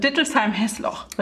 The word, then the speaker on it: Dittelsheim-Hessloch: Achtung, jetzt muss ich es richtig aussprechen. Dittelsheim-Hessloch, richtig? Ja, du kannst Hessloch weglassen.